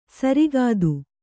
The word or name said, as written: ಸುರಗತಿ